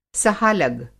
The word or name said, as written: सहालग